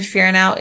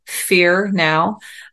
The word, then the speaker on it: Fearnow: My last name, Fearnow, is spelled like it sounds, F like Francis, E-A-R-N-O-W, Fearnow.